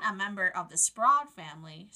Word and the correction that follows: Sprague: Sprad is it? I'm sorry, how do you pronounce it? – Sprague. – Sprague family. Sprague family.